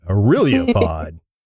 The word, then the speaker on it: Aurelia Pod: I have no idea how many times I said it wrong or how I said it wrong, but I was saying it completely wrong for a long time. Called in with a voicemail. Hey guys, it's Damien from Aurelia Pod. I was like, oh, that's how you say it. Aurelia Pod. Say it with me, Stephen. Aurelia, because he was saying it wrong too.